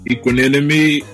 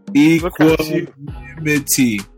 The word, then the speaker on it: Equanimity: Equanimity.